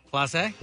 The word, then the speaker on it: Mintz-Plasse: Christopher Mintz-Plasse, is that how you say it? – Oh, yeah, you know him. – Yeah, McLovin. – Yeah, I didn't know if you'd say it Plasse. – Plasse? – Plasse.